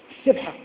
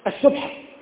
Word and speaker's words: السبحة: نعم المذكر السبحة يعني هذا موضوع وكذب والكلمة تنطق بضم الباء وليس كما ننطق نحو لك السبحة السبحة غلط السبحة.